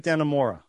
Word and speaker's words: Dannemora: Escape at Dannemora.